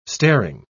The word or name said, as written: staring